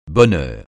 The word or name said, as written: bonheur